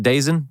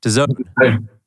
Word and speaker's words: DAZN: How do you pronounce it? Dazen? Dazen? – DAZN.